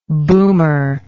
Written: boomer

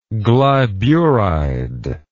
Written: glyburide

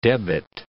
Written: debit

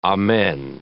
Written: amen

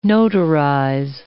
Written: notarize

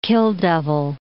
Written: Kill Devil

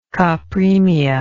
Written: copremia

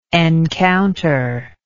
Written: encounterer

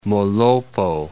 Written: Molopo

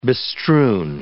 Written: bestrewn

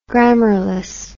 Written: grammarless

